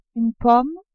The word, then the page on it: pomme: French